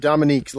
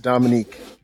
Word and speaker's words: Dominique: Yep, Dominique. A lot of people say Dominic, but it's Dominique.